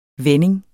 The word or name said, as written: vending